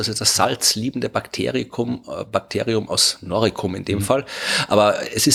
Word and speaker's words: Noricum: Ja, das ist umstritten, aber ich glaube wahrscheinlich in dem Fall Noricum wird man es mit K aussprechen.